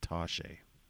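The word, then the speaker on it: touché: It's pronounced Toshe.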